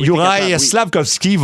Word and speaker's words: Juraj Slafkovský: Ça veut dire que le premier choix du Canadien, je vais tenter de bien le prononcer, Juraj Slafkovský va avoir une occasion de briller.